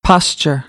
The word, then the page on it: posture: American English